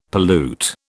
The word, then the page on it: pollute: British English